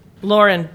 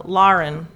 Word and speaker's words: Lauren: I mean, my name has always been Lauren, but in Cincinnati, like, people say my name, Lauren. – Lauren. – But when I moved here, I would tell people my name was Lauren, and they'd be like, I don't know. I couldn't, like, get people to stop saying Lauren. – Yeah. – Well, I wasn't even trying to get them to stop saying Lauren. – It's almost like an Irish pronunciation.